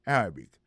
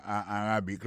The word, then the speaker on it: Arabic: Arabic là, c'est comme ça, on dit arabe en anglais. Arabic. A-R-A-B-I-C. Golem d'ABC, prononcez moi arabique là.